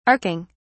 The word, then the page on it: arcing: British English